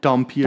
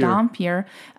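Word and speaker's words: dhampir: He's actually was called, I'm going to say this wrong, a Dampier? – Dampier. – Dampier, which is basically like a human vampire hybrid.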